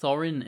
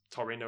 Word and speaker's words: Thorin: I think I always liked Thorin in the books. – Mm. I grew up saying Taurine because of a Taurine. BBC cast version that had been done, which repeatedly mispronounced that as Taurine Oakenshield.